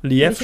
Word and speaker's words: Liev: Liev Schreiber? Lief Schreiber.